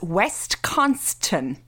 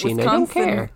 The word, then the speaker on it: Wisconsin: And I just want to tell you this, after all the abuse that you gave me this fucking podcast, you earlier on pronounced Wisconsin as West Constant. And I just want to highlight the fact that one of us here knows that Wisconsin is pronounced Wisconsin, not West Constant.